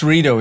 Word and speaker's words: Rito: So, how do you say your name usually? Like Rito or Rito? – It's Rito, yeah, but I don't really like mine.